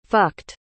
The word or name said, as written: fucked